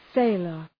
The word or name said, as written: sailor